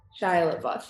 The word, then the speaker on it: Shia LaBeouf: Is that how you say his name? I don't know how to say his name. – Shia LaBeouf, yeah.